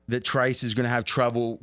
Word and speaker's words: Trice: Okay, sit back and bear with me a second here because I want to talk about the Steelers' six round pick, Corey Trice. And is that the right pronunciation? That's correct. Thank you.